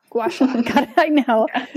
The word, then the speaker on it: gua sha: And I can never, ever pronounce this correctly. The gua shia? Gua shia? How do you say that? – Gua sha.